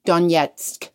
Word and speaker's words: Donetsk: How do I say that? – Donetsk.